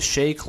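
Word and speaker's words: Schaake: No, it's spelled S-C-H-A-A-K-E. I'm saying shake. – Oh, okay. – Should it be Shaq, you think, or Shaky? I'm going to keep saying shake.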